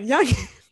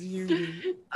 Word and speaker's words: Yoongi: Yes, because I used to say his name wrong, and it was one of my friends. He was like, "What you call him?" I'm like, Youngin. His name is Yugi.